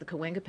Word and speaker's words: Cahuenga: It's pronounced the Cahuenga Pass, which runs right through present-day Hollywood, which at that point didn't exist.